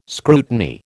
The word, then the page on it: scrutiny: American English